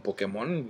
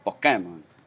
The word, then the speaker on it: Pokémon: allá le dicen Pokémon, güey. No sé dónde chingón les, sale el acento en la E, güey. Pokémon.